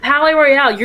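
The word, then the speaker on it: Palaye Royale: It's Palais Royale, you're doing fine.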